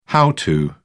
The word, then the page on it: how to: British English